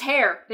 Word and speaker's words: Haigh: But it's pronounced Hare. – They make it very Hare. – They make it very clear that that's how it's pronounced. But H-A-I-G-H does not spell Hare.